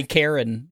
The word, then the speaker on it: Charon: It's literally Karen, you know, so there you go. – Well, I'm going to call him Charon.